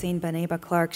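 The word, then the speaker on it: Maxine Beneba Clarke: Can you talk about Maxine Beneba Clark? Am I saying that correctly? – Yeah, I think it's Maxine Beneba Clark.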